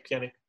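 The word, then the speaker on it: Pjanić: Pjanic, how do you say it? – Yeah, Pjanic.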